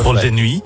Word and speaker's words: Vol de Nuit: Vol de Nuit.